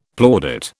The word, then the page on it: plaudit: British English